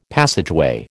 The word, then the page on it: passageway: American English